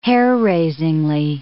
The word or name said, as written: hair-raisingly